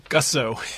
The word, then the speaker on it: Gussow: Scott Balcerek and Adam Gussow. – Gussow. All right. I figure I'll let you guys pronounce it. – Yeah, Gussow. Might as well.